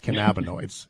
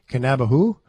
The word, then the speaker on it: cannabinoids: Opie knows his stuff, and Ryan and I are trying to pronounce cannabinoids. I say that because that matters to everybody who's listening. Look, if you're like me, and if you're going cannabahu, right?